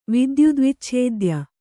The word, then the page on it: ವಿದ್ಯುದ್ವಿಚ್ಛೇದ್ಯ: Kannada